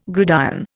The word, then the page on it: gridiron: Indian